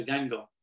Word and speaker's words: Guingamp: And also I had Anthony Knockout at the club at the time, again, who I'd been to France and watched him play for Gango, spelt Guingamp, if you're looking at the table. But it is called, they call it Gango. That's how they say it.